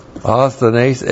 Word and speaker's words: asthenes: Osthenes, A-S-T-H-E-N, long E-S, osthenes.